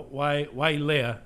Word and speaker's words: Wailea: This week I've been in beautiful Maui um, and Wailea, uh, Wailea, is how you say it, um, at, at, the Wailea Beach Resort, um, courtesy of T Mobile.